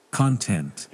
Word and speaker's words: content: So this is now, and you have to remember where the stress is. Content or content. The first or second syllable. So we pronounce con with high note.